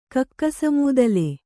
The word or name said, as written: ಕಕ್ಕಸಮೂದಲೆ